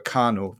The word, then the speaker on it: Khanal: And on the other, you talk about a Turkic or a Khanal. That's spelled K-H-A-N-A-L after Khan.